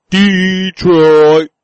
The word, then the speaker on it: Detroit: Yeah, they're a real team compared to Detroit. – You need to say it right. Detroit.